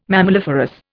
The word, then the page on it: mammaliferous: Indian